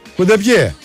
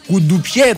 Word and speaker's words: κουντεπιέ: Κουντεπιέ. – Κουντουπιέ το κουντουπιέ λες. – Ε. Όλοι κουντουπιέ το λέγαμε.